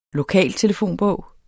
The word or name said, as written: lokaltelefonbog